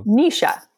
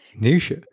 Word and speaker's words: Naoise: So the spelling of the name, I'll spell it for you, is N-A-O-I-S-E, was wow. – Nisha. – Nisha. – Yeah. – It's almost like Saoirse.